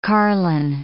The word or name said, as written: carline